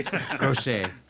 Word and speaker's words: crochet: I, I said it wrong. Crochet. Crochet.